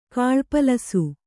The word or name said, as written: ಕಾಳ್ಪಲಸು